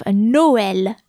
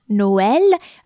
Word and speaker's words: Noël: And for the tréma, you have Noël, Noël, which means Christmas, and it is spelled N-O-E with a tréma, so with two little dots over it, and the letter L.